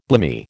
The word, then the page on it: blimey: American English